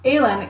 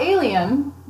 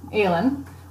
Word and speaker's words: Alein': The Aileen, A-L-E-I-N apostrophe, not alien, Aileen, Whalers, W-H-A-L-E-R-S, the Aileen Whalers.